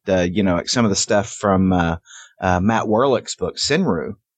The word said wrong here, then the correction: Senryu: Am I pronouncing that right? – Senryu, I think. – Senryu.